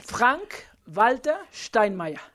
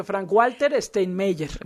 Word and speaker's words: Frank-Walter Steinmeier: Frank-Walter Steinmeier. – Muy bien. – Sí, Frank-Walter Steinmeier. Okay. Sí, no vayan a salir con que Frank-Walter Steinmeier. No.